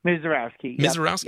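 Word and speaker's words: Misiorowski: I don't want to butcher his last name, but I believe it's Mizorowski. – Mizorowski.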